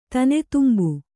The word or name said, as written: ತೆನೆತುಂಬು